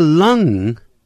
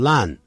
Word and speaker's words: lung: 而这个 lung 念的时候可别念成 lung, 要念成 lung, Lung 就是肺部的意思。